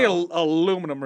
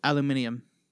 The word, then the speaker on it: aluminium: C-U-M. – I've never seen that before. – Commercial. – Yeah, how do you say? They say aluminum real funny too. – Aluminium. – Aluminium. – Aluminium. – I don't know. – It's aluminum to us. – It's not. That's not how you pronounce it.